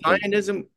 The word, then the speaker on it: Messianism: He has published 5 nonfiction books on Jewish mysticism, and I'm hoping I'm pronouncing this right. Messianism? – Perfect. – Messianism? People get it.